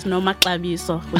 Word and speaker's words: Nomaxabiso: Okay, it is pronounced as Nomaxabiso.